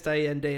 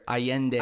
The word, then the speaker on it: Allende: Allen or Carlos Miguel Allende or Allende. – Allende. – Whatever. – It's a double L, jackass. – Who's to say that's how he pronounces it? – Allende.